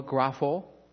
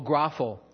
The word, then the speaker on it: grapho: Close? No, grapho. There was no S at the end. I have it pronounced here. Oh, grapho.